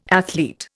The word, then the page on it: athlete: Indian